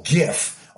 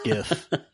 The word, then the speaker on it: GIF: You're saying it right. – Well, I say Rob says it wrong. You said it right. Because it comes from graphics interchange. So it's like a GIF. You got it right.